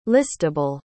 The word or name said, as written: listable